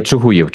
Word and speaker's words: Чугуїв: Так, перепрошую, у вас Чугуїв наголос на «ї»? Буду знати. – Ні, Чугуїв ми говоримо.